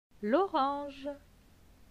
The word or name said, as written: L'orange